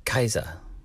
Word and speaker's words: Caesar: Um, you know, and Julius Caesar would not have been Julius Caesar; he would have been Julius Kaiser. Kaiser, you know, just like the Germans.